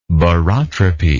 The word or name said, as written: barotropy